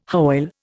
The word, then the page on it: Hoyle: Indian